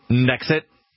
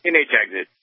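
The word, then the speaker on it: NHexit: Is it NH exit or is it Nexit? – NH exit. – Okay. – NH exit. – I've been saying it wrong.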